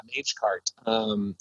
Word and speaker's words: Magecart: But anything about web skimmers, and I'm not sure if I'm even pronouncing this right, is it Magecart or Magecart? Magecart? – Yeah, Magecart. It's a Magecart is a particular skimmer that was targeted.